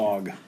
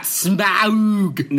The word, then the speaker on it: Smaug: Smaug. – Smaug. – Smaug. Not Smaug.